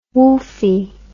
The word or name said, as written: woofie